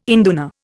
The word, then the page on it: induna: Indian